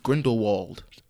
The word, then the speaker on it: Grindelwald: Fantastic Beasts, The Crimes of Grindelwald.